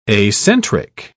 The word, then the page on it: acentric: British English